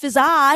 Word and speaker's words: Fizaa: My name is Fizaa. It's not spelled F-Z-A. It's spelled F-I-Z-A-A with two A's at the end, but it's not pronounced Fizaa. It's just pronounced Fizaa. One of the A's is silent.